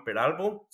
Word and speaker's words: Peralvo: En esta ocasión tenemos el gusto de tener con nosotros a, Juan Peralvo.